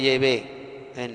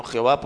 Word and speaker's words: Yahvé: Se le conoce como el ángel de Yahvé, no Jehová, porque Jehová es un nombre falso totalmente.